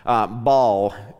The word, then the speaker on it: Baal: Baal is his name.